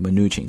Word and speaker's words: Mnuchin: 这句话的主语呢是 Steven Mnuchin 啊，这个人呢现在也非常的有名，比如说我们中美贸易之间啊来回的磋商谈判啊，他也算是非常重要的这个代表了，叫做穆怒琴啊，Steven Mnuchin，他名字当中的这个第一个 N 字母不发音啊，就是 Mnuchin 这么读出来就好了，然后往后看叫 told a press conference。